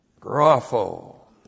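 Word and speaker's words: Grapho (γράφω): Grapho, G-R-A-P-H, long O, grapho. And grapho is the Greek word that means to write.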